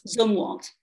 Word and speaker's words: Zumwalt: Am I saying that right? Zumwalt? – Zumwalt. – Zumwalt. – Yes.